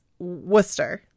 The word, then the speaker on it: Worcester: The thing is, the R is silent. And the C sounds like an S. So it's not like Worcester. It's not like it looks like Worcester, but it's Worcester.